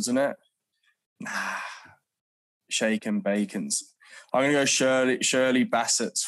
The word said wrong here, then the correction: Bassey: from... Bassey, sorry. I can't even say her name. Shirley Bassey.